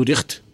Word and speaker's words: riechst: Du riechst, όχι ρίχτ.